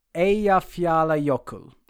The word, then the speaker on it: Eyjafjallajökull: Eyjafjallajökull. I believe they use soft J's in Icelandic.